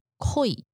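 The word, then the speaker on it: 鯉: 就就是观赏用，然后呢，这个大家觉得鲤鱼，因为鲤鱼的发音就是 “koi”，“koi” 这个字呢，就跟恋情的“ 恋 ”“koi” 一模一样的念法。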